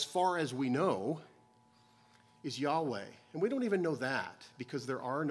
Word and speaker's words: Yahweh: Yahweh in the Hebrew has no vowels, and so your neighbors or your potential friends who want to convince you that it absolutely must be pronounced Jehovah are either unaware or dishonest about the fact that there is no letter J in the Hebrew language.